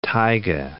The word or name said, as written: taiga